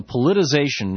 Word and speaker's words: politicization: It's called the politicization or politicalization, whatever the word is. Do you know how to pronounce that word? – Politicization. – Politicization. Thank you.